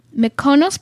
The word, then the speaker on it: Mykonos: So, basically, the colors we've reviewed so far, emerald, Mykonos blue.